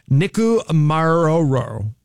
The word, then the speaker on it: Nikumaroro: A 17-member team from Purdue University and the Archaeological Legacy Institute, they call it ALI, has launched a high-stakes expedition to Nikumaroro. Let's see. I owe it to you to get this right. Nikumaroro. Maroro. I said it right.